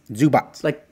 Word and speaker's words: Zubac: Zubats? – Zubats. – Like the pants? – No, like Divots, but Zubats.